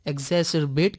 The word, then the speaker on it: exacerbate: एग्जैसरबेट का मतलब होता है किसी समस्या, बीमारी या कठिन परिस्थिति को और अधिक गंभीर या बदतर बना देना।